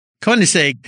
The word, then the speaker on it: Koenigsegg: The guy's name is Christian von Koenigsegg. – Koenigsegg, yes, Koenigsegg, yes.